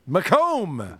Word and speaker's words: Macomb: Macomb. – Wreck me. – That is how it's spelled, too. I probably should have gotten that one right. Oops. – I'm a comb salesman.